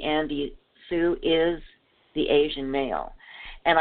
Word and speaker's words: Tsou: His name is Andy Sue, and that's spelled T-S-O-U.